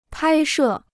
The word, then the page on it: 拍摄: pāi shè